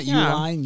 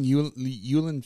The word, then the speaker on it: Uline: It's not Uline, Yulin Field.